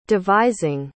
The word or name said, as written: devising